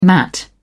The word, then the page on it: mat: British English